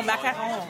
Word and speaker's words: macaron: Oh, macaron.